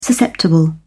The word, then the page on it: susceptible: British English